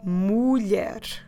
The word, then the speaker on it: mulher: And the word is mulher. Mulher. Let me spell it for you. I'm Português. M-U-L-H-E-R. Mulher.